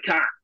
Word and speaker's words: cunt: And then the way Australians say it, it's kind of like K-A-R-N-T, like cunt. Like, you fucking shit cunt. But when Americans say it, it's so edgy. Like, you really emphasize the T.